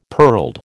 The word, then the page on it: pearled: American English